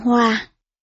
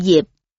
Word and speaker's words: Diệp: Lưu Diệp ở phần trước đã phiên âm là Lưu Hoa, thật ra chữ này đọc là chữ Diệp hoặc Diệp.